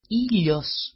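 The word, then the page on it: ήλιος: i-l^os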